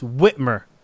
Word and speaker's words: Whitmer: It's not silent. It's Whitmer. Sorry. – Yeah. – I thought the W was silent.